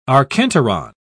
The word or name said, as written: archenteron